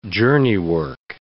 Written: journeywork

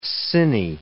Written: cine

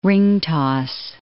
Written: ringtoss

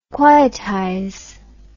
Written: quietize

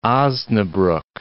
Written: Osnabruck

